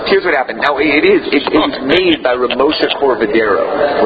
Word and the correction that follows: Cordovero: Ramosha Corvadero is the one that added on these. The what? – Cordovero. – That's how you pronounce it? Oh, you're right.